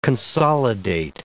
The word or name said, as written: consolidate